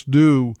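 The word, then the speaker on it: dhow: Now, if you've never heard of a DOU, D-O-U is not how it's spelled. It's actually spelled D-H-O-W, D-H-O-W, but it's pronounced DOU like D-O-U.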